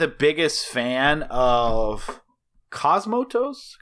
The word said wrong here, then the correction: Cosmatos: Panos Cosmatos.